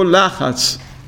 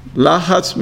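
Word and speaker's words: Lachatz (לחץ): So you know in Hebrew, stress, how do you say stress in Hebrew? Lachatz. Lachatz means stress. You know Hebrew? No. Lachatz, lamed tzadi het, means stress, problems.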